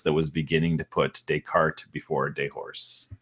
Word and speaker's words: Descartes: And by the way, guys, it is actually, his name is pronounced Descartes without the either of the S's, I guess, which makes it even funnier.